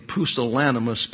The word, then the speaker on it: pusillanimous: We have become a pusillanimous people. I've mispronounced that in the past, but I said it correctly today.